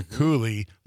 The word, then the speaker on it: Coulee: That's spelled C-O-U-L-E-E for Cooley.